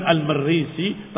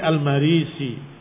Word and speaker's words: al-Marisi: Dia adalah nisbah kepada Marisah bis Sa'id wal masyhur bi takhfifir ra, yang lebih masyhur bukan al-Marisi tapi al-Marisi. Ra-nya tidak ditasdidkan.